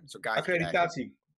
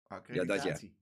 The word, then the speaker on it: accreditatie: ik wil zeggen, Akeri, Akeri, ik kan het woord niet uitspreken. – Accreditatie. Accreditatie. Ja, dat ja.